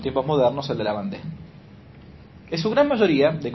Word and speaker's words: Vendée: Vendée en francés es V corta E-N-D-E-E. Vendée, ¿no? Vendée con acento en la anteúltima E.